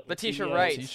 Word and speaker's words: Letitia Wright: Letitia Wright.